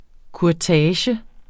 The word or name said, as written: kurtage